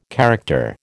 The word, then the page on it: character: American English